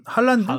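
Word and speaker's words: Haaland: 그러니까 뭐 현지 중계라든가 들어보면 혼란 할란드 할란드, 할란드, 할란드.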